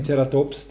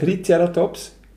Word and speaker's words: Triceratops: Du bist auf der ersten Silbe. Nein, ich tue es auf, auf die erste, aber dann äh, Tricer Triceratops. Also du hast zwei Erhebungen drin. Okay. Ich ja. habe eine, die mittlere nämlich, Triceratops.